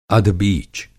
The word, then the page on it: अधबीच: Hindi